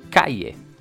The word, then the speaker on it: calle: På spanska så blir de här två L:en i Kalle till ett J. Och därför skulle man uttala det som Calle.